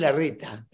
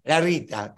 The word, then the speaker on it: Larreta: ¿Cómo es que este tipo, que Horacio Rodríguez, ahora es la Reta? Es el infierno donde mira. – Claro. – Es un calabrés. Bueno, un Trump. No, es un Larreta. Larreta. Pronúnciase así la R, doble R. Larreta.